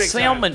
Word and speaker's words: salmon: He calls it salmon, time. – Frank. Salmon. So he knows what's up. – He pronounces the L.